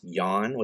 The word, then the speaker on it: Jan: It's spelled Jan, but it's pronounced Jan, which I love, is actually celebrated for his innovative use of lighting throughout this film.